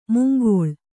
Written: ಮುಂಗಾಳು

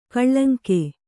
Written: ಕಳಂಕ

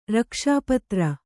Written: ರಕ್ಷಾಪತ್ರ

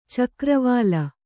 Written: ಚಕ್ರವಾಲ

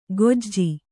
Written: ಗೊಜ್ಜಿ

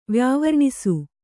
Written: ವ್ಯಾವರ್ಣಿಸು

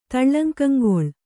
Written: ತಳ್ಳಂಕಂಗೊಳ್

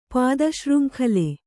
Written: ಪಾದಶೃಂಖಲೆ

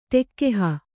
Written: ಟೆಕ್ಕೆಹ

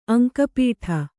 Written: ಅಂಕಪಟ್ಟಿ

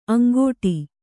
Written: ಅಂಗಾಡಿ

